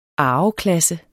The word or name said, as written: arveklasse